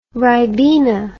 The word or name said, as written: ribena